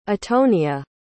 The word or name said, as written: atonia